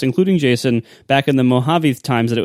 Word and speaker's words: Mojave: It is Mojave. He was right.